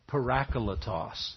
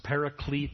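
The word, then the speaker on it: Parakletos (παράκλητος): One of the favorite names that is used of the Holy Spirit in the New Testament is the word parakletos. Maybe you've heard it pronounced paraclete. That's not how it's pronounced in the original language. Parakletos.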